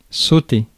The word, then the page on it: bound: /ˈbaʊnd/; American English; [baʊnd]